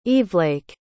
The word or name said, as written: evelake